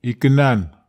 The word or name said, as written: iken an